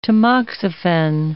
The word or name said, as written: tamoxifen